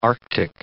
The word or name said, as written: arctic